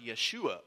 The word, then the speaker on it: Yeshua: But another Joshua was coming, and that, of course, is Jesus Christ, because in Hebrew, Jesus is pronounced Yeshua or Joshua.